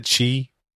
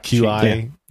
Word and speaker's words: Qi: Chi. – Yeah. – Qi.